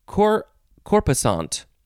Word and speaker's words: corpusant: It is corpusant or corpusant with an S or Z sound.